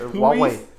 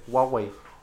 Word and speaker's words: Huawei: Hawaiian. He's phone? The one that taught me how to say it. Huawei. Huawei.